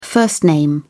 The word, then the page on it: first name: British English